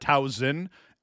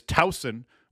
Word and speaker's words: Towson: I believe the proper pronunciation is Towson with a soft S, not that hard Z sounding S.